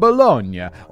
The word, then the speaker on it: Bologna: Is it Bologna? Bologna? I feel like I absolutely should know that. Come on, pronunciation man. Tell me what you got. Bologna. It's not Bologna for sure. – Bologna. – Bologna. Okay. – See, correct though.